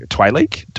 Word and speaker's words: Twi'lek: Like, I get not every Twi'lek is supposed to be Twi'lek?